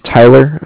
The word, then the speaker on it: Tyler: Just write down that my name is Tyler, T-Y-L-E-R, and that I'm calling with Cardiff. It's spelled C-A-R-D-I-F-F, as in Frank.